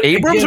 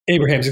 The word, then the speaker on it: Abrahams: Is it Abrams or Abrahams? – Abrahams, excuse me. Yeah, Abrahams.